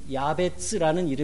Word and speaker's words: 야베츠: 그런데 이 이름은 어, 히브리 말로 히브리 말이죠 이름이 히브리 말로 오체브라고 하는 단어에서 자음의 순서만 바꾼 야베츠라는 이름의 영어식 발음입니다.